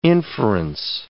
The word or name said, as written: inference